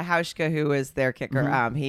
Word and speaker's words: Hauschka: It's Hauschka.